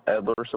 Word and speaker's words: Edward: Edward Sepulveda. – Can you pretty please spell that for me? – E-D-W-A-R-D. Last name is Sepulveda, S-E-P-U-L, V like Victor, E-D-A.